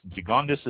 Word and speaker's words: Gigondas: Gigondas is spelled G-I-G-O-N-D-A-S, Gigondas. And I know that's correct because I have a pronunciation here.